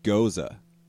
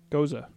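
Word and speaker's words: Gose: Oh, it's pronounced Goza. – Goza. – Goza. – Got the Goza. Wait, what? – Yeah, like goes. – Got to Goza? – No, goes. G-O-E-S. – Goes. Okay. – And then U-A-U-H.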